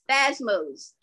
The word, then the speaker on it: spasmos: And when the Greek word, it said it was spasmos. Am I correct? – What is the word again? – It says spasmos. S-P-A-S-M-O-S. – Spasmos. – Spasmos. Thank you. You said, I forgot what you pronounced it right.